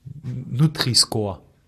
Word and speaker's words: Nutri-Score: Nutri-Score. Du kannst es Englisch aussprechen. Es ist ein englisches Wort. – Nutri-Score.